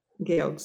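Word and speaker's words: gewog: Gezas. – Georgs. – Georgs. – So yeah. It's spelled G-E-W-O-G-S. It's like a crazy spelling, but it's pronounced Georg.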